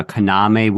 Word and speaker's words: Kaname: I'm just noticing that you're pronouncing his name a Kaname, which I believe is the correct way. I believe it was the accent on the second syllable, but I had forgotten it.